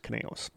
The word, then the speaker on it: Conejos: I believe it's pronounced Conejos. K-O-N-E-J-O-S. Yes.